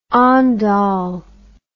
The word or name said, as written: ondol